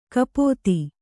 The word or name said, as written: ಕಪಿತೆ